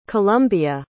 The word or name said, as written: columbia